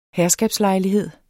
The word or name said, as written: herskabslejlighed